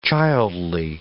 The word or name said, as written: childly